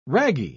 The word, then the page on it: ragi: British English